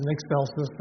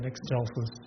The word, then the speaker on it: excelsis: And by the way, it can be pronounced in Excelsis or in Excelsis, either one.